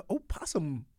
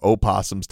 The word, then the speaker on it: opossum: So I was sitting there going, yeah, why are we in the opossum farm or something? – And when did possums stop being Irish? – Huh? – When did they go from opossums to possums? Nobody ever pronounces the O.